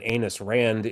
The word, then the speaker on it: Ayn Rand: point where they understand that Ayn Rand is probably not one of our favorite people. Did I mispronounce that? – Nope, you did not. It's pronounced exactly like that.